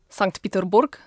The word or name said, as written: St Petersburg